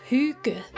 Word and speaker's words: hygge: It's that time of year to get cozy, or as the Danes say, higgy. Hygge? Hygge? – It's pronounced hygge.